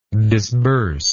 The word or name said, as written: disburser